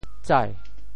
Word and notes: 载: tsai2

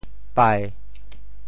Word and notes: 唄: bai7; Teochew

